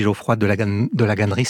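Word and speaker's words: Geoffroy de Lagasnerie: Il y a votre ami Didier Ribon, votre ami Geoffroy de la Gannerie, c'est comme ça qu'on prononce son nom, qui vous formez un trio inséparable et très mystérieux.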